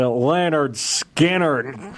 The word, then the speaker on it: Lynyrd Skynyrd: They pronounce it Lynyrd Skynyrd.